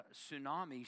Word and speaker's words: tsunami: For instance, the word tsunami starts with a T, but the T is silent.